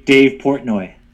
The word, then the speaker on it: Portnoy: You're probably saying his name right, and that's why I don't recognize it. Just to let you guys know, okay, so, say his name again. – Dave Portnoy. – Okay, and we've been calling him Dave Potney, a Dave Potner here on the podcast. They're like, hey, we call him Potner.